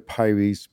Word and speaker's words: Pires: My name is Alexander Pires, P-I-R-E-S. Doesn't really rhyme with anything.